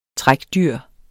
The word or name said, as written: trækdyr